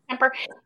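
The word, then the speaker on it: Kuemper: So behind your defense, you guys are getting some pretty lights out goaltending from both your starter, Antti Raanta, and your backup, Kemper. Am I saying that right? – Yeah. – Kemper.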